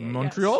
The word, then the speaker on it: Montreal: That's how Canadians in English say it. – Montreal. – Yes. Montreal. – Montreal. Like that? – Americans have this like Montreal. It's Montreal.